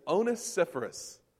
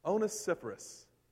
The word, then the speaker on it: Onesiphorus: but his name is Onesiphorus. Onesiphorus. Everybody say that together. Onesiphorus.